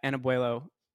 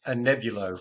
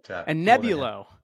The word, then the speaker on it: Anebulo: Joining me today is the CEO at Anebulo Pharmaceuticals. Did I say that right? – It's not an easy one to pronounce. Anebulo Pharmaceuticals, but Anebulo. Yeah, Anebulo.